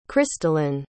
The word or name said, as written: Krystalyn